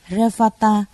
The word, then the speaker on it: Revata: Ngài Li Bà Đa, Ấn Độ đọc là Revata, chữ Hán là Tinh Tú, là bậc không điên đảo, không rối loạn đệ nhất, tức là ngồi thiền định.